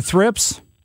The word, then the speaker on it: thrips: It was about thrips. Am I pronouncing that correctly? – You mean the thrips? – Thrips. – Yes. – Thrips. How do you spell that? – T-H-I-R-P-S.